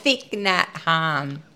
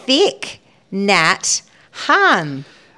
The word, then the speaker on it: Thich Nhat Hanh: It says Thich Nhat Hanh. – Okay. Three words, guys, for you to Google. T-H-I-C-H is the first word. N-H-A-T, then H-A-N-H.